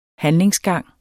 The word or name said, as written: handlingsgang